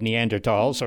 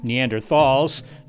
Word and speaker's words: Neanderthals: The stereotype of Neanderthals, or Neanderthals, you're going to hear both pronunciations in this episode,